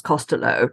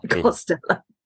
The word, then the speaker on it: Costello: As, as, has been pointed out to me in Ireland, it's actually pronounced Costello, not Costello.